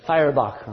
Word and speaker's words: Feuerbach: Feuerbach.